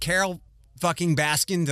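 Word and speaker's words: Carole Baskin: Okay, so I, I think everybody believes that Carole fucking Baskin.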